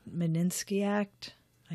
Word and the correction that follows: Magnitsky: Magnitsky Act.